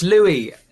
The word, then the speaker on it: Louis: Yeah, it's Louie. It's a silent Louis. – S. Yeah. Silent S.